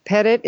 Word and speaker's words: Petitt: It's K-A-R-L-E-N-E. Pettit is P-E-T-I-T-T. And I'm also on Twitter at Carlene Pettit, and I love people emailing me questions.